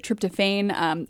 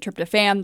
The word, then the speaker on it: tryptophan: Wortman pronounces it tryptophan. Other people pronounce it tryptophan. They're both correct.